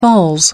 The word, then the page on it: falls: British English